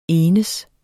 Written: enes

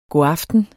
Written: godaften

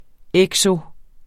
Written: ekso-

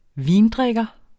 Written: vindrikker

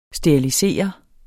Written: sterilisere

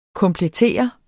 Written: komplettere

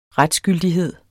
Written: retsgyldighed

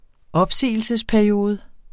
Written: opsigelsesperiode

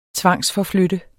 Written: tvangsforflytte

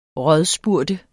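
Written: RÅDSPURGT

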